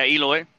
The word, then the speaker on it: Eloy: Yeah, Eloy.